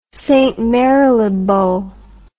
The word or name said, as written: St Mary le Bow